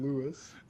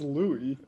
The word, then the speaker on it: Louis: I don't know why it's funny to call him Lewis. – What is it? – I think it's Louis. – Louis. Yeah, Lewis. – The S is silent.